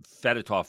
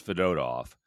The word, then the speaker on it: Fedotov: Yeah, we were calling him Fedotov forever, but in Russian, the middle syllable is the big one, apparently. – Oh. – So it is Fedotov.